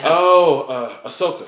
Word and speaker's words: Ahsoka: Ariasha? – Who's that? – From the Clone Wars, the one that Ahsoka.